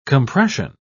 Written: compression

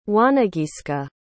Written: wanageeska

